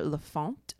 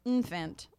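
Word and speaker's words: L'Enfant: In 1788, the council commissioned architect Peter Lafont. – Wow. Great name. – Lafont. – Also love the way it's spelled. LENFANT. I would not have said Lafont. – Lafont. – I would have said infant.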